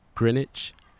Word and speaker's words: Greenwich: Recording from somewhere in North Greenwich. That's how you pronounce it.